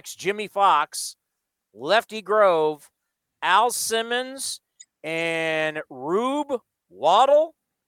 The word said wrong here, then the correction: Waddell: Rube Waddell.